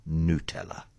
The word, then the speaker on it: Nutella: And what do they say about how it's pronounced? They say it is pronounced Nutella.